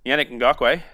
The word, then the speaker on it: Yannick Ngakoue: Yannick Ngakoue.